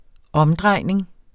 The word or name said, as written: omdrejning